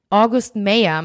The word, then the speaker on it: August Meyer: Sie fahren zu seinem Familienfreund August Meyer. August Meyer, meine ich natürlich, ein bisschen deutsch ausgesprochen gerade.